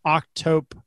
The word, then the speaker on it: octopodes: Second, the plural of octopus is fairly vigorously debated, but because the word derived from Greek, not Latin, the most etymologically correct plural isn't octopi, but rather octopodes, are pronounced octopodes, Mind you, pointing that out to people is pretty pedantic, and we usually get you branded as an insufferable know-it-all.